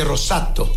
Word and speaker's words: rosato: Pero italiano se dice rosato.